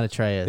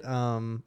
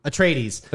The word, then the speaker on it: Atreides: No, it's not Atreus. – Is it just broken in my brain from God of War? – Atracidus or what is it? Atreides. – Atreides. – Atreides. Okay. House Atreides.